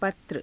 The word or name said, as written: patra